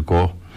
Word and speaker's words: Koog: zeg ik dat goed? Ik zeg het uit mijn hoofd. Ja, uit de Koog.